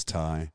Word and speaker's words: Ty: By the way, everybody, my real name is Ty, T-Y.